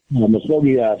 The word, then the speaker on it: Muscogee: Muscogee, from Ojibwe.